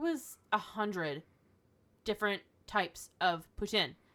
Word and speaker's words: poutine: All my life, I grew up calling it poutine. – Yeah, because he's English. – Apparently, the proper pronunciation is poutine. – Poutine. – Yeah. – It's the French word.